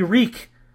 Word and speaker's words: Reek: He is only Reek. It rhymes with freak.